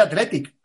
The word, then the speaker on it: Athletic: En Bilbao se dice Athletic. Pronunciando más o, más o menos fuerte la C, pero no dicen el Athletic Club, hablando de ellos mismos.